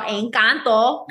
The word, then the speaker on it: Encanto: Gotta say it right, y'all. Encanto.